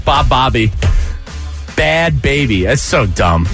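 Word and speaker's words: Bhad Bhabie: Isn't it just bad? Baby, but just bad baby. That's what it is. But there's H's in there.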